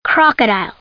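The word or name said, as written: crocodile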